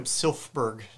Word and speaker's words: Silfverberg: Jacob Silfverberg. I know it's like extra letters there, but yeah. – It's like French. You only pronounce half the letters.